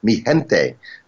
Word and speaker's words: Mi Gente: And I just learned the correct pronunciation. It's Mi Gente, the Henry Fong remix, no relation, J Balvin and Willie Williams.